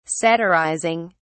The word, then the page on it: satirizing: British English